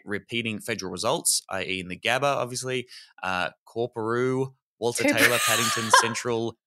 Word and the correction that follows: Coorparoo: It's Cooperoo. Corporoo. – Cooperoo? – Yeah. – Have they misspelled it or is there an R? No, you've said it wrong. – It's spelled C-O-O-R-P-A-R-O-O and it's pronounced Cooperoo? – Yeah, Cooperoo, Coops.